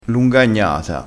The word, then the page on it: lungagnata: dialettale